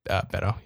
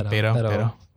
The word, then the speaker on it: Beto: And just to give you a feel for who's on here, number one advertiser right now is, I don't know if I'm saying his name right, Beto. – I think it's Beto. Yeah, Beto.